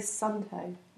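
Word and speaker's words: Suunto: So if you're a Sunto user, how are we pronouncing that? Sunto? Sunto? – I just say Sunto.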